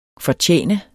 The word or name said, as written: fortjene